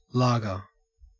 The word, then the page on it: lager: Australian English